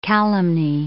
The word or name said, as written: calumny